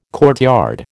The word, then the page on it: courtyard: American English